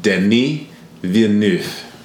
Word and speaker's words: Denis Villeneuve: So I'm going to teach everyone right now how to say his name. Okay, you ready? Denis Villeneuve.